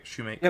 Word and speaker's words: Shumake: Shumake? – Yep. – Yeah. Because my brain's like, Schumacher. No, it's... both race car drivers, yes, but no.